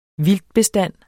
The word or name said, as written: vildtbestand